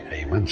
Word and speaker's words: Hayman: Heyman, sí, sí. – Es, claro. – En, inglés se pronuncia así. En español sería Heyman pero eh, en inglés Heyman